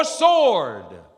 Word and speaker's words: sword: Danger, you need security, or sword. That's how you pronounce it. Not sword, it's sword. The W is silent.